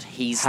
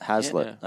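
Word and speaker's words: Heazlett: There's a bit of hate out there for Sam Hazlitt's selection, and I'm reliably informed that the pronunciation is Hazlitt. – Hazlitt, okay. – Not Heaslet. Hazlitt.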